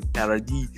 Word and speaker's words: Paradis: and then you have Connor McGovern, who came in after Paradis, and then or Paradis, whatever. Paradis, I French, though. So I pronounce it the French way. Paradis, and then you ended up with McGovern.